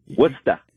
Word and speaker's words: Worcester: Yeah, Worcester.